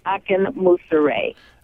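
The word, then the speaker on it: Akinmusire: His last name is Akin Musare.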